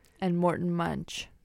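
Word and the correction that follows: Münch: I think it's actually Monk. – Oh, that's my bad. There's a little accent on the U.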